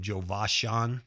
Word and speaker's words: Jovashan: Jovashan, Jovashan Ward, Jovashan. It's like three names put in one Joe and Vosh and Sean. Jova Sean.